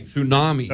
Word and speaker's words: tsunami: With the silent T and everything, tsunami.